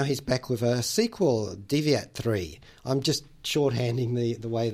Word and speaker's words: DEV1AT3: Lifelike and Deviate.